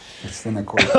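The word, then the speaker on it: arsenokoitai: Arsenokoite, right? – Is that it's arsenokoite. Koitas. – Oh, yeah. Arsenokoita. – Arsenokoitas. – Koitas. – Arsenokoitas. Okay.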